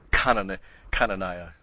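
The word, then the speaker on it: Chananiah: Actually, it should be Cananiah is the way you'd pronounce it in Hebrew, because the first, the little starts with a cough, which is C-H, actually, a heart. You know, you go, it's like you're choking, but Cana.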